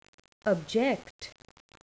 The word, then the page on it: object: verb